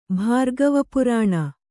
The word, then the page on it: ಭಾರ್ಗವಪುರಾಣ: Kannada